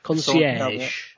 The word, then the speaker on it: Concierge: Yeah, so it's going to be called Pokemon Consurge. I've probably butchered that word. I will paste that in the chat and someone can tell me. Excuse me? What it's actually called. Concierge. Concierge, yeah.